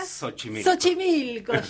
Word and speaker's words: Xochimilco: Xochimilco.